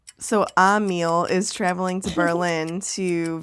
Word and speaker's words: Emil: Emil. – With an A, even though it's spelled with an E.